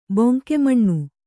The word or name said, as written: ಬೊಂಕೆಮಣ್ಣು